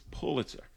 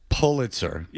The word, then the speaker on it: Pulitzer: No, he was like Pullman, like Bill Pullman. This is Pulitzer. Pull. Is Pull. There's Paul. – Pulitzer.